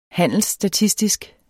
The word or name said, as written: handelsstatistik